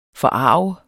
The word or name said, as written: forarget